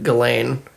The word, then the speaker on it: Ghislaine: Ghislaine? – Mm-hmm. – It's not how it's spelled, but Ghislaine.